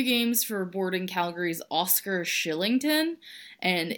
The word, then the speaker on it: Kylington: It's spelled Kylington. – Yes, but it's pronounced Shillington. He's a Swede. I don't know if that's how Swedish works, but that's how you say his name.